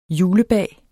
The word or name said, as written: julebag